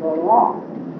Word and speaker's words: Laurent: And Mother and Pop Redfield both used the French pronunciation and called him Laurent.